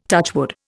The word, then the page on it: touchwood: Indian